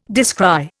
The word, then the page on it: descry: Indian